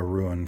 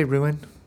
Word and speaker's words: rune: And the idea that you can transfer sort of a rune from one item to another. – Did you say ruin? It's rune, right? – Rune.